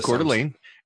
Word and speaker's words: Coeur d'Alene: Anyway, no, so it's pronounced Coeur d'Alene, and it was in Kootenai, Kootenai County. Little, that's how it's pronounced. I thought it was Yee-hoo.